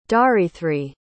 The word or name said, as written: dharithri